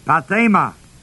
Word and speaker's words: pathema: The Greek word is pathema, P-A-T-H, long E-M-A, P-A-T-H, long E-M-A.